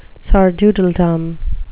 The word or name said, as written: sardoodledom